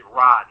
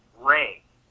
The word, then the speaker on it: Ra: And Ra is actually, you know, we pronounce it Ra now, but in ancient times, it would have been pronounced more like Ray, like Sun Ray.